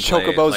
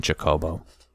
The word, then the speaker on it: Chocobo: It was a Chocobo. You're pronouncing it wrong. – Goddamn drink. Chocobo. Avocado. – It's a Chocobo. You're putting the emphasis on the wrong syllables.